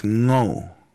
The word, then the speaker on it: ngō: And how you pronounce it is ngō.